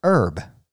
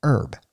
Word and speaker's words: herb: But in the United States, except in New England, the word is usually pronounced herb, E-R-B, herb, which sort of imitates the way the French pronunciation might be.